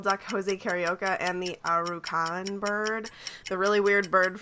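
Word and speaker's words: Aracuan: But I think it's pronounced Arucan. – Arucan. – Yes. – Yeah, that is.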